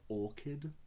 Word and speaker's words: orchid: I think it's pronounced orchid. – Orchid? – Yeah. Orchids. – What did I say? – Orchards. – No! – Oh, no. – Orchards are different things.